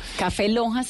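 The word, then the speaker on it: Café LOHAS: Café Lojas se escribe L-O-H-A-S. – Café Lojas. Así es.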